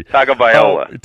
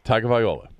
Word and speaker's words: Tagovailoa: Tagliavova. – I know. And not even close. – You know what? I have to look at it phonetically to say it correctly. – Tagovailoa. – Tagovailoa, right. Yeah, Tagovailoa.